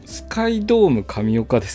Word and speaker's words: 宙ドーム神岡: スカイドーム神岡